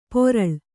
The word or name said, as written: ಪೊರಳ್